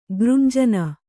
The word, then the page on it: ಗೃಂಜನ: gřnjana